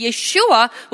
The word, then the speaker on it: Yeshua: In English, yes, Jesus, but in his original pronunciation, it's Yeshua, which means the Savior, and that he is the righteous Savior for his people.